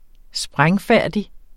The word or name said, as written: sprængfærdig